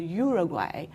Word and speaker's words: Uruguay: But in Spanish, you pronounce it as Uruguay. So when I was in Uruguay, most people who, you know, meet me or, you know, I visited, they pronounce it as Uruguay. So I adopt Uruguay when I speak along.